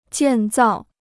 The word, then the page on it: 建造: jiàn zào